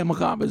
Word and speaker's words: macabre: Is that the right word? – Yeah, macabre is how you pronounce it.